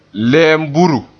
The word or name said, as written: lemburu